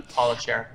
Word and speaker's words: Scher: Paula Scher.